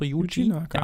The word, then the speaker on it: Yuji Naka: So Yuji Naka.